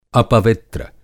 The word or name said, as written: अपवित्र